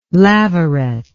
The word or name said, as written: lavaret